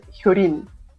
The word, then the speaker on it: Hyo Lin: Mi, la mía es Hyurin, pero no sé por qué, por qué, pero en mis documentos aparecen con L, así que, pero tienen que pronunciar como R del radio, ¿sí?